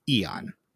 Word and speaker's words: Æon: Apparently, according to Google pronunciations, it's Æon.